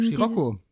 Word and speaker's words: Scirocco: Ähm, Scirocco. – Scirocco? – Na klar, kennst du nicht den Volkswagen Scirocco? – Nee. Und dann spricht man wirklich so auch, auch, auch auf Italienisch. Scirocco.